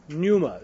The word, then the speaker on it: pneuma: But nevertheless, the first word is pronounced pneuma. It's a silent P. Well, it's a silent pi, actually.